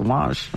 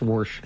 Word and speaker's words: wash: And I used to look at them and go, there's no R in wash. It's wash. – I like it. – They always thought it was me saying it wrong because I used to drop my R's coming from New England. – That's funny. – So I used to get grief for calling it wash. It's Warsh.